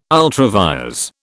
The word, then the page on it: ultra vires: British English